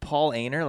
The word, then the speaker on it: Paulaner: Paulaner, like, yeah, so Paulaner? it's the name Paul and then A-N-E-R.